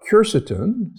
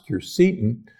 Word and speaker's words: quercetin: The pith contains large amounts of polyphenols and also phytonutrients, including a compound that you've heard about, quercetin, sometimes pronounced quercetin, that is present in the pith of citrus.